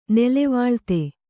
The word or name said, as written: ನೆಲೆವಾಳ್ತೆ